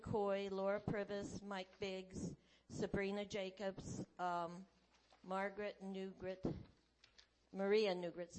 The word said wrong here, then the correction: Negret: sorry about that. Negret. I regret mispronouncing it.